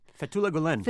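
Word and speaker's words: Fethullah Gülen: Fethullah Gulen? – Fethullah Gulen.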